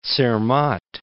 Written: Zermatt

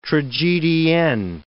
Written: tragedienne

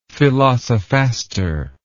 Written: philosophaster

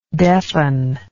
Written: deafenedness

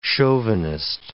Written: chauvinist

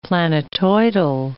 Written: planetoidal

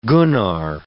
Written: Gunnar

